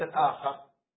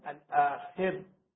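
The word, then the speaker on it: الآخِر: واليوم الآخر وليس الآخر لا ينطق الآخر the other day it's wrong we don't pronounce it as the other day الآخر ولكننا نقول اليوم الآخر آخر last day يعني أنه ليس وراءه يوم meaning there is no day after كيف ستكون الحياة أبدية سرمدية؟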